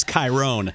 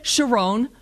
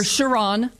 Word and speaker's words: Chiron: I'm obsessed with the car, which I incorrectly pronounce as Chiron. And I have been, by the way, I even do that with Steven Winkleman when I'm talking to the former CEO of Bugatti. And you can say it correctly. – Well, I would say Chiron. – Yes. – Or Chiron. Depending on if you're French or not.